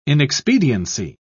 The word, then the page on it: inexpediency: British English